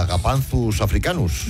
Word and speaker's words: Agapanthus africanus: Porque es mucho mejor llamar Josefina o Pepita a una planta que a Agapanthus africanus.